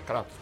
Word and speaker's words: Kratos: na verdade a pronúncia certa é Kratos, porque é, é grego.